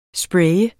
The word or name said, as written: spraye